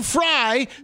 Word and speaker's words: Frey: Anything write it F-R-Y. It doesn't matter. The audience isn't seeing it spelled F-R-Y, but 100%. Write fucking Frey the way it's said.